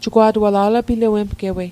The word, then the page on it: alapilawemgewei: Mi'gmaq